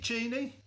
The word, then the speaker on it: Jeannie: Okay, so I'm joined today by Jeannie. Is it Jeannie? – It is Jeannie, yes.